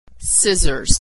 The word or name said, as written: scissors